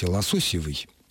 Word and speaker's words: лососёвый: Игорь Александрович спрашивает, почему в слове «лососёвый» ударение ставится на третьем, а не на втором слоге? И так ли правильно? – Да, именно потому, что Елена Николаевна правильно произнесла его с «ё», а «ё» всегда ударно в русском языке.